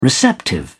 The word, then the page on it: receptive: British English